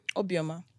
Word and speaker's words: Obioma: So how do you say your last name? – Obioma. – Obioma.